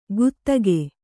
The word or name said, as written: ಗುತ್ತಗೆ